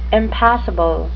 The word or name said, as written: impassible